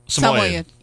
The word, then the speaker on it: Samoyed: Looks like the same dog, which is a Samoyed. Is that how you say it? – Samoyed.